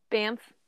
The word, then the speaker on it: Banff: The week that we released the Mansfield Reformatory, we also released one of the Baffin. Is that how you pronounce it? – Banff. – Banff. There you go.